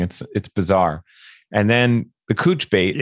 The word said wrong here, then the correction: chooch: I mean, I'm sorry. The chooch. Sorry. – The chooch. – Chooch. I wrote it down and I said it wrong too. I don't know how to spell that word. I wrote C-H-U apostrophe C-H like chooch.